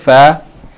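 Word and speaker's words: Fa (فاء): That is lesson number 22, the letter Fa. By the way, the letter Fa, as we mentioned last week, its pronunciation is similar to the F in the word fat or food.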